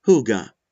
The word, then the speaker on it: hygge: Hygge, pronounced hygge.